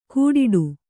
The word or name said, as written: ಗೂಡಿಡು